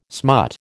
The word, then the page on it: smut: American English